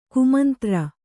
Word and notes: ಕುಮಂತ್ರಿ: kumantri

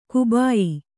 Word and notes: ಕಬಾಯಿ: Kannada

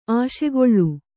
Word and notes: ಆಶೆಗೊಳ್ಳು: Kannada

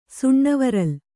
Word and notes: ಸುಣ್ಣವರಲ್: Kannada

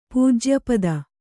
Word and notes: ಪೂಜ್ಯಪದ: pūjya pada

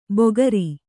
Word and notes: ಬೊಗರಿ: bogari

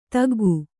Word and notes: ತೆಗ್ಗು: kannada

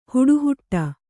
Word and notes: ಹಟಹಿಡಿ: Kannada; haṭa hiḍi

